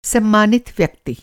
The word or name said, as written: सम्मानित